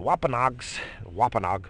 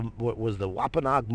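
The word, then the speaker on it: Wampanoag: And the Wapanoag, I think I say it right too. I think I say it right. And that's because I grew up Wapanoag.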